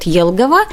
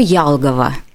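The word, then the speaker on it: Елгава: Город Елгова в русской транскрипции пишется из буквы «Е», да, а чат GPT написал нам с буквы Ялгова. Да, мы понимаем, что ну вот на латышской манере или в латышской транскрипции, если говорить, то есть йод читается между е, е и я.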